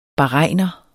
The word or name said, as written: bahrainer